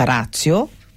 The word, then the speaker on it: ratio: Poi c'è ratio, che questa è proprio la pronuncia, è latina, credo sia proprio il termine latino scritto ratio, ma che si pronuncia ratio.